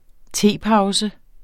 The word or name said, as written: tepause